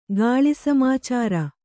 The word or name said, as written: ಗಾಳಿಸಮಾಚಾರ